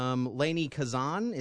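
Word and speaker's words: Lainie Kazan: Lainey Kazan, is that how you say her name? – Yeah, she's 77.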